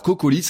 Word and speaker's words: Cocolyze: Alors CocoLise, ça s'écrit coco comme une noix de coco, hein, C-O-C-O, et Lise, L y-Z-E.